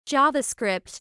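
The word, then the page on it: JavaScript: American English; /ˈdʒɑːvəˌskrɪpt /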